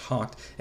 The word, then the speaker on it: hough: They hough the horses, H O U G H, that's how that word spells, is spelled and it's pronounced hocked,